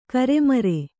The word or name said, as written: ಕರ್ಮೀರ